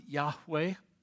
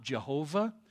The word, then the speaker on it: Yahweh: It's best pronounced Yahweh. God's name is not Jehovah. In the Hebrew language, there is neither a J or a V. They could not have physically said Jehovah.